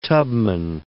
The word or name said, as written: Tubman